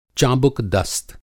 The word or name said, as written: चाबुकदस्त